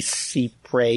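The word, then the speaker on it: cy pres: I always thought it should be CIPRE because that's how you pronounce C-Y in French.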